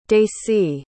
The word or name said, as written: dacie